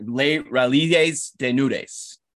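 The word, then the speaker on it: Les Rallizes Dénudés: I'm going to butcher this shit, but Les Raisins des Nudes. How the fuck do you say that shit? – Les Raisins. Okay, it's like Les Raisins des Nudes. – Les Raisins, whatever.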